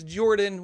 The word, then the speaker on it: Jorphdan: My name is Jordan, with a silent PH in the middle, and I am joined always by my wonderful co-host, Sir Lucian, over at Sir Lucian Gaming.